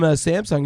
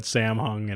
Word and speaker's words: Samsung: I mispronounced Samsung and accidentally said Samhung, and everybody laughed at me.